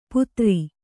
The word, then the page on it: ಪುತ್ರಿ: Kannada